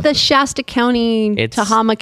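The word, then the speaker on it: Tehama: In Northern can you pronounce the name of the county right? – No, Tahama. – Where does it say the county? – To Yakahama, Yamazuchi. – Tahama. It's Tahama. There's three vowels.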